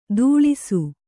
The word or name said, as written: ಧೂಳಿಸು